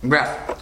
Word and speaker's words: Wrath: ¿Cómo se pronunciaba el Artu? – Wrath.